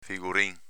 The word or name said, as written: figurin